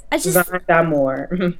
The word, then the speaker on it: Zahn D'Amour: Cause yes, Zahn D'Amour.